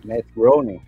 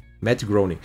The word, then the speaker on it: Matt Groening: Matt Groening. – Matt Groening.